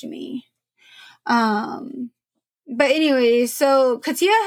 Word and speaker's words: Katya: Katia, which this one, it's one of those names that I did have to look up. I'm not even going to lie. It's spelled K-A-T-Y-A, but it's pronounced Katia.